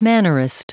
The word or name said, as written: mannerist